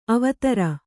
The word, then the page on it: ಅವಧಾರು: avadhāru